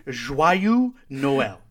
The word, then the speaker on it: Joyeux Noël: There's no way you pronounced that correctly. – Joyeux Noël. I looked it up, and it's Joyeux Noël.